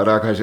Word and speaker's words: rakas: Okay, wie spricht man es richtig aus? – Ja, das heißt, schönen Abend. – Rakas. – Hyvä ilta, Rakas heißt.